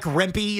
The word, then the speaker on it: Rempe: This Mike Rempe, is that how you say his name? – Yes, Rempe. Rempe, who's about 8 feet tall.